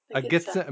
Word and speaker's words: Gitxsan: a member of the Gitxsan Nation. I hope I said that right. – Gitxsan. – A member of the Gitxsan Nation.